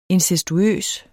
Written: incestuøs

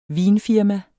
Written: vinfirma

